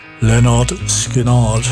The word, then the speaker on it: Lynyrd Skynyrd: And this one is taken from the album, pronounced Lynyrd Skynyrd, or similar, like that.